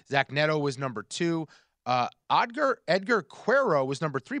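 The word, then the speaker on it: Edgar Quero: Edgar Cuero is how you pronounce Cuero.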